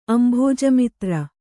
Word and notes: ಅಂಭೋಜಮಿತ್ರ: kannada

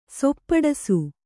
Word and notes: ಸೊಪ್ಪಡಸು: Kannada

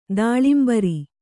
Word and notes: ದಾಳಿಂಬರೆ: kannada

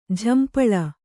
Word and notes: ಝಂಪಾಳಿ: kannada

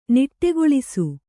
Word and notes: ನಿಟ್ಟೆಗೊಳಿಸು: niṭṭegoḷisu

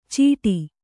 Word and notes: ಚೀಟಿ: cīṭi; Kannada

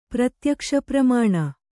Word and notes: ಪ್ರತ್ಯಕ್ಷಪ್ರಮಾಣ: pratyakṣa pramāṇa

